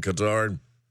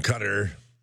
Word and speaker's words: Qatar: The new Air Force One that President Trump received as a gift, $400 million gift from Qatar, or the other pronunciation, Qatar.